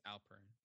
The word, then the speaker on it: Alperen: It's Alperen.